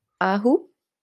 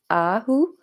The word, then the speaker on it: Ahu: Ahu. Short U, long A, Ahu.